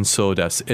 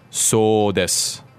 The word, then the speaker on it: そうです: And it's important to note here with the vowel in so desu, it's a long O. So desu.